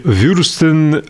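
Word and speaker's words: Wurst: Или Вюрстен, нет, Вюршен им Шлафрок. Как правильно произносится по-немецки? Правильно? Вюрст, вюрст.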